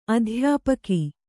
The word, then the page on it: ಅಧ್ಯಾಪಿಕೆ: adhyāpike